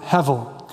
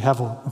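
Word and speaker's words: hevel (הבל): That's a soft B in Hebrew. That's pronounced like a V, hevel, okay?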